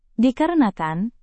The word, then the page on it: dikarenakan: indonesian